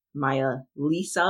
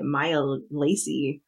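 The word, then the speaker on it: meyaah Liessa: So in my head this entire time, I was not pronouncing it Lisa. In the like the audio, it literally sounds like Maya Lisa, like the name Lisa. I've been in my head, I've been calling it Maya Lacey.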